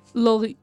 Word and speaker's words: Laurie: Sí, debe ser como Lori. – Lori. Claro, nosotros decimos Lauri. Laurie. Laurie, porque tiene la E al final.